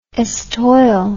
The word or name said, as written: estoile